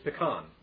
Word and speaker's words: pecan: One of the words that was pronounced differently was the word P-E-C-A-N. Now, most of us know that word is pecan. But he pronounced the word pecan. I said, well, the word's not pronounced that way. It's pronounced pecan.